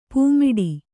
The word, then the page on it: ಪೆಮ್ಮಾಡಿ: Kannada